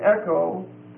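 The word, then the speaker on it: echo (ἔχω): It comes from two words, sun, S-U-N, which is together with, and then echo, E-C-H-O, long O, which means to have or to hold.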